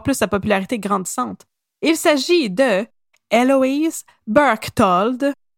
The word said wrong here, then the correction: Berchtold: J'ai bien de la misère à prononcer son nom, je vais vraiment en faire comme de mon mieux. Mais c'est une, une américaine en plus, ah, c'est Eloise Berktold.